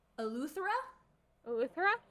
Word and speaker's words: Eleuthera: In 2016, the cruise line was looking to buy Egg Island Elthera? – Don't look at me. This is... Welcome to another episode of Gabby and Emma Don't Know How to Pronounce Things. – Elthera, I'm going to guess. – Eleuthera, Bahamas, as another destination for the additional cruise ships.